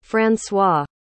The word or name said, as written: françois